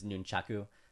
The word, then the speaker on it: nunchaku: Yeah, the proper pronunciation, if you're going to pronounce it the Japanese way, is Nunchaku. So Japan has five vowel sounds, A-E-O-U. – Okay. – So Nunchaku is the proper way to say it.